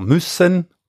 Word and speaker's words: müssen: Müssen, dovere, noi diciamo Messai, quindi müssen in tedesco c'è l'accento sulla prima vocale, noi in ladino di solito la vocale cade sul, sul finale della parola, di solito o molto spesso sulla vocale eh, finale, e quindi müssen diventa messai.